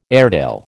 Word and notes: Airedale: American English

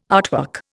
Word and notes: artwork: Indian

